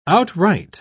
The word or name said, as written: outright